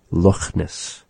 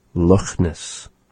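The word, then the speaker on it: Loch Ness: Have you ever heard a Scotsman pronounce the word Lochness? So he pronounces that CH in the word just like how we say the Kh, Lochness. Lochness.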